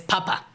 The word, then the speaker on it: Papa: Por ejemplo, la palabra alemana para decir papá es papa. – Papa. – Con la primera sílaba acentuada, como le dice Eleven a papa en Stranger Things.